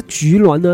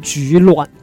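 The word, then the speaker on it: 居銮: 那菊峦呢，他们本地人的话会把它发音成菊峦，第三声菊峦。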